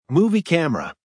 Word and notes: movie camera: American English

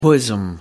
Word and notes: bosom: American English

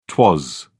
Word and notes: twas: British English

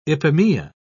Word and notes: ipomoea: British English

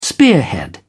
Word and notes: spearhead: British English

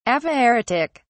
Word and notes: aphaeretic: British English

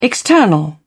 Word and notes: external: British English

